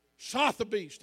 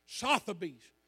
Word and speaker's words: Sotheby's: Y'all ever heard of an auction house called Sotheby's? I may not even be saying it right. How you say it? Sotheby's. Thank you. Sotheby's.